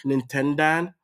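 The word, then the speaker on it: Nintendaan: And honestly, the only reason that I thought about it is that someone on Twitter, Nintendan, I'm saying it the American way. Nintendan, it's spelled the Dutch way, D-A-A-N.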